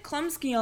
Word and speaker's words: Chlumsky: Well, they said it Chlumsky on the Emmys.